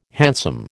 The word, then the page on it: hansom: American English